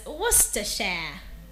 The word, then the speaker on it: Worcestershire: It is pronounced as Worcestershire. Worcestershire.